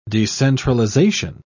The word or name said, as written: decentralization